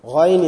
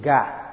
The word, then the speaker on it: غين: Ghain is very hard. People, you'll hear them reading ga. Ga. Which is wrong. Gairil Magdubi. Gairil. Gairil. There is no even ga in Arabic.